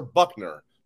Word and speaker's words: Buchner: Tyler Buchner. It's pronounced like Bill Buchner. It's spelled a little different.